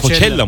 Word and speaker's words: Coachella: Coachella diye mi okunuyor hocam bu? Coachella diye bir Coachella. Festival var. Coachella mıdır? – Evet, abim oradaydı.